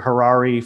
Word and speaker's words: Harary: But we've got Robert Harary from Evolution Venture Partners. I hope I pronounced that right. It almost rhymes with Ferrari.